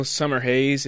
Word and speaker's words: Summerhays: Daniel Summerhays is in play right now in the U.S.